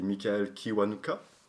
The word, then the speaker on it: Michael Kiwanuka: mais il y a aussi Michael Kiwanuka, si je le prononce bien.